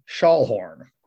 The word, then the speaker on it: Schallhorn: It's Kaitlyn Schallhorn.